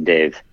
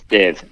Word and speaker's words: Dev: And my parents always called me Dave for short, which is, it's how you say in an Indian accent, Dev. It's like, pronounced like that. It's Dev.